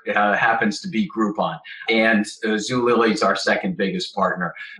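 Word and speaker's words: Zulily: How would you classify Zoot, Woolily, Zoot? Hold on. Let's just talk about my mispronunciation. I said Zoot and Woolily. I meant Woot and Zoolily, a Groupon, things of that nature.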